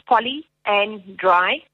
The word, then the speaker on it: polyandry: Okay, so we're going to start with polyandry. So it's poly and dry. So if you pronounce it like that, if you break it up, mm-hmm.